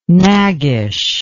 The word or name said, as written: naggish